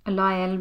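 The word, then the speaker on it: Lael: Så CNN satte vel sine kort på hun Lyle Brainard? Lyle, som man uttaler det.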